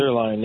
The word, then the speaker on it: Zuerlein: I always mess up his pronunciation, Greg Zuerlein or Zuerlein. I want to say it's Zuerlein. – Greg Zuerlein, yeah. – Yeah. And I think that... – Like the steak, kind of.